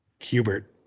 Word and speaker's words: Kubert: 9 is Andy Kubert or Kubert. How do you say that? – Kubert. – Kubert, okay. – Like the video game.